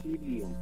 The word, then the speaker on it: psyllium: Y quiero decirte que hay una planta que se llama psyllium. Psyllium, así como lo escuchas, que empieza con la P, la E, la Y, pues pronuncia psyllium.